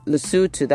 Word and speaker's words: Lesotho: Then you could have names of places like Thai, Thailand, Lesotho. Lesotho, that's the way to pronounce that word.